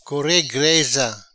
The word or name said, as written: Core Greza